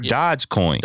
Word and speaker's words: Dogecoin: No, there is an E. It's just pronounced Dogecoin. I don't know why. – Oh, okay. All right. I'm about to say, okay. – There's 100% an E. It's Dogecoin.